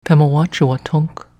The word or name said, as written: Pemawatchuwatunck